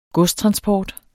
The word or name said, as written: godstransport